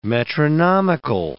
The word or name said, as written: metronomical